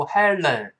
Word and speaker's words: Helen: 注意这个 l 的发音，舌尖注意不要像汉语拼音一样勾舌头，不是读 Helen，不是 len len。注意，舌头没有弹射这个动作，舌尖往前指，抬起来跟上牙膛粘一下。